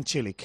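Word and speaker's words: Marin Čilić: ¿Ves? Se dice Cilic. – Cilic. Venga, pues a partir de ahora voy a decir Marin Cilic, sí, que juega Cope contra Carlos Alcaraz ahora en un ratito.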